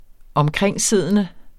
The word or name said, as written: omkringsiddende